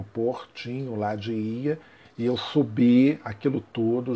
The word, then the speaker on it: Oia: É, chama-se IA. A gente escreve OIA, mas pronuncia-se IA.